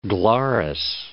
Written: Glarus

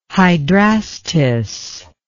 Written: hydrastis

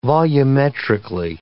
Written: volumetrically

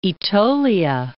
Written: Aetolia

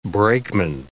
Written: brakeman

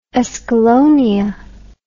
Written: escallonia

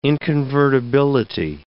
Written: inconvertibility

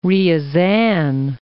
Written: Ryazan'

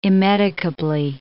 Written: immedicably

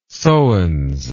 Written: sowens

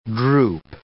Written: drupe

